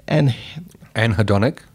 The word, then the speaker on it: anhedonic: How do you say it? – Anhedonic. – anhedonic, there we go, is the inability to feel pleasure in normal pleasurable activities.